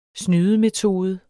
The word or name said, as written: snydemetode